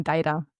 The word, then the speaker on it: Madeira: Also die Betonung. Also wahrscheinlich sagt man eher auf Madeira Malvasia. Also ähnlich. Man sagt ja auch nicht Madeira, sondern Madeira.